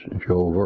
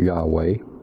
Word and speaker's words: Yahweh: The Hebrew name for God as revealer is Jehovah or Yahweh. I'm not going to argue with those who pronounce it either way, so I often pronounce it both ways, which is translated in our King James text and most of the other texts simply as Lord.